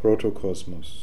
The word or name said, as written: Protocosmos